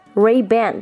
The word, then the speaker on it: Ray-Ban: Ray-Ban 当做一个 hyphen，发音就跟中文的雷朋有点相近啊。 Ray 发 Ray 的音，Ban。当中这个 a 有的人发 a，有人发蝴蝶音 a 啊，可以发是什么？Robin。